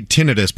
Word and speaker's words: tinnitus: I've always said tinnitus, but I think it is tinnitus. – I thought it was tinnitus. – No, it's technically tinnitus, but I won't say that.